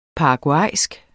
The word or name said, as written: paraguaysk